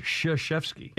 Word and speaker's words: Krzyzewski: It's pronounced Krzyzewski. – It's like crazy Zewski.